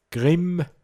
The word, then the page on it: gschweareg: Pinzgauer Mundart